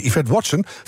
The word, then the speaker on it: Yvette Watson: En ons groene geweten is deze keer Yvette Watson van de V-Factory. – Yes! – Eindelijk goed uitgesproken na 30 jaar.